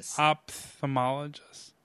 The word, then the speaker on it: Ophthalmologist: It's like an opromologist. Ophthalmologist? Ophthalmologist? Right? Isn't that? – The problem is, that this one's unfair to you to read. That H between the P and the T is silent.